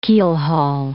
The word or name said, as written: keelhaul